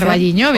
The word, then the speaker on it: O Carballiño: O Carballiño, no lo sé pronunciar. – O Carballiño.